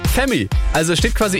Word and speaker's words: F3MIII: Ja, der wird nämlich ganz anders geschrieben, der wird so F3MIII geschrieben. Also gut, hätte man auch auf Femi kommen können, aber ihr wisst, was ich meine.